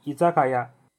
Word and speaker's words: 居酒屋: 日文的发音是伊扎卡亚。